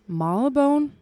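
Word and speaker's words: Marylebone: og nærmere bestemt i Marlbone. Ja. Udtaler jeg det korrekt? – Ja, det er det. Marlbone.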